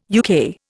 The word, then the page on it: UK: Indian